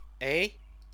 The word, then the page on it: ei: Chinese